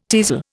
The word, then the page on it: teazel: Indian